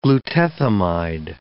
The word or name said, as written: glutethimide